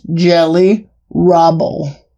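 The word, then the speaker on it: Geli Raubal: No, that's how it's pronounced. Jelly Rabel.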